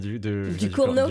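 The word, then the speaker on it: Ducournau: On pense notamment au Titan de Julia Du Cournot.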